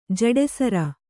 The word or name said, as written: ಜಡೆಸರ